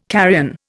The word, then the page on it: carrion: Indian